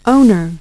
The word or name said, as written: owner